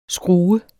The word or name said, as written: skru